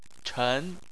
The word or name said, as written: chen2